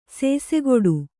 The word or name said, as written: ಸೇಸೆಗೊಡು